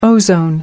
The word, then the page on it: ozone: American English